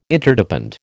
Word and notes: Interdepend: American English

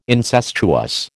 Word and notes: incestuous: American English

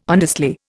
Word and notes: honestly: Indian

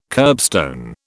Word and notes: kerbstone: British English

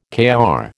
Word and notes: Kr: American English

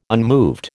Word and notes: unmoved: American English

